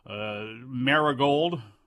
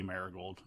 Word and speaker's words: Marigold: Rossi Ogawa's new group, Marigold, is apparently how it's pronounced. It's going to be Marigold. It's the way it goes.